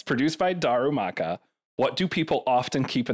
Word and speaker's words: Darumaka: Darumaka. – Darumaka.